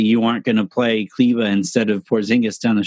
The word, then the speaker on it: Kleber: Kleber, I thought was Kleba, I think Kleba is how you say it.